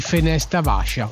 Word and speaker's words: Fenesta Vascia: Allora, adesso ci andiamo ad ascoltare questa tua interpretazione di Fenesta Vascia. L'ho detto bene? Fenesta sì, Vascia. Ottima pronuncia.